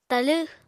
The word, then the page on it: talẃ: apatani